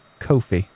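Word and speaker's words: Ko-fi: Now, you have a number of places that people can subscribe for your content, including Fanhouse, and I've been told it's pronounced Kofi, like Lofi.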